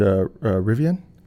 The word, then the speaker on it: Rivian: You know, we touched on, you'd mentioned Rivian.